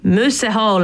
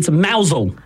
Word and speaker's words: Mousehole: It's probably something like moose hole. – That's just offensive. – Or is it like mooshole? – Oh, it's actually a mousel. – Mousel. – But it's spelt mousehole. – It is spelt like mousehole.